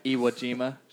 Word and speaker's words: Iwo Jima: Iwo Jima, just like Jagger.